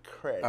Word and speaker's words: Creed: Tienes que pronunciar así. Creed.